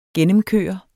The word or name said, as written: gennemkøre